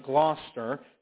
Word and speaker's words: Gloucester: I know it doesn't look like that. If you ever look this up, it doesn't look like that, but they pronounce it Gloucester, during the English Reformation.